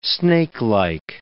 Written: snakelike

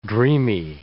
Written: dreamy

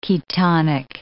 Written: ketonic

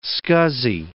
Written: scuzzy

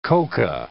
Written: coca

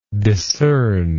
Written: discerner

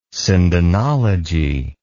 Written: sindonology

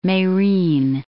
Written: Merin

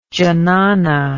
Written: jnana

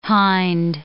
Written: hind

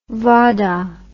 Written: vahdah

